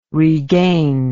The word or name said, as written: regain